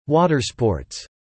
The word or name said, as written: watersports